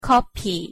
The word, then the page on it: 커피: Korean